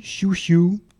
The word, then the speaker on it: Xiu Xiu: Donc, je vais vous passer Chou-Chou. Ça s'écrit Xiu Xiu, hein, mais c'est à la chinoise.